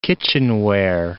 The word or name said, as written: kitchenware